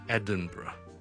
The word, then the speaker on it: Edinburgh: Edinburgh Edinburgh E-D-I-N-B-U-R-G-H 一个非常之漂亮的城市，咁但系咧，个发音咧系同个串法咧好似唔系好大关系，好多人咧见到 Burgh 就会再读成 Burgh 或者 Burgh 咁样，咁啊其实唔系嘅，我哋后面嗰咧系一个好简单嘅 Burgh so Edinburgh 就唔好读成 Edinburgh 啦。